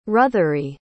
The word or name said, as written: ruthery